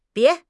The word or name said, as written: bie2